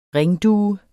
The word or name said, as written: ringdue